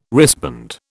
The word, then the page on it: wristband: British English